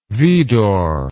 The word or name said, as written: vidor